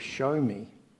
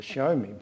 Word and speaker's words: Xiaomi: I bought this flash Chinese phone, and according to Phoebe, it's called a Xiaomi. It's, if we spell it in English, it's X-I-A-O-M-I. Is it Xiaomi? That'll do.